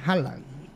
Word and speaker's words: Haaland: Erling Haaland. Ajá, con H. Pero como nosotros lo decimos con J, ¿va? – Ajá, la H la pronunciamos como J, ¿va?